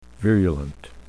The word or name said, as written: virulent